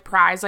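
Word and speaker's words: Pryes: So it's the Raspberry Sour from Prize Brewing, and it's spelled P-R-Y-E-S. I know it sounds like prize, like P-R-I-Z-E, but it is not.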